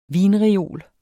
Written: vinreol

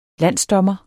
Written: landsdommer

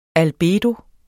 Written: albedo

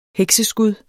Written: hekseskud

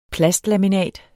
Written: plastlaminat

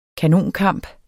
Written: kanonkamp

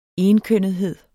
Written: enkønnethed

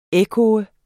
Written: ekko

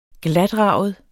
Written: GLATRAGEDE

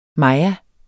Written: maya

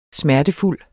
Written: smertefuld

